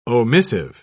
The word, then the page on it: omissive: British English